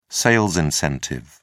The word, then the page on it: sales incentive: British English